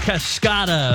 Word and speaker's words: Cascada: Actually, it's pronounced Cascada. That's okay.